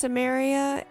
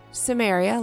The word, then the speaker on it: Cimmeria: The way they pronounced Cimmeria in the first episode made it sound like Sumeria, and without subtitles, I never would have known.